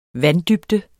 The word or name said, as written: vanddybde